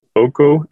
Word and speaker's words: OKO: OKO, to start with, it's pretty funny, actually, because the company is called AYES, but the product is called OKO, and you spell it O-K-O. But OKO actually stands for human eye in some Slavic languages.